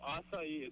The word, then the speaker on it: acai: It's called acai. It's spelled A-C-A-I, but you pronounce it acai.